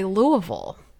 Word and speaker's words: Louisville: The locals say Louisville. You say it like you're just so put out with...